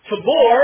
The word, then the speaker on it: Tabor: Tabor, and that's the correct way to pronounce that, I learned in Israel, and Hermon, which is the way the Israelis pronounce it. Tabor and Hermon, shout for joy at your name.